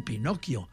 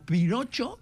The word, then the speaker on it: Pinocchio: perquè en italià es diu Pinocchio. No? I en castellà, que no sabien bé com se pronunciava, van començar a pronunciar Pinocho. Llavors els catalans, que tenim la dèria calcadora, de la qual sempre parlo, la dèria calcà, tot el que fa el castellà, doncs vam agafar i diuen Pinocho, doncs en català Pinocho, ja ho tenim, no?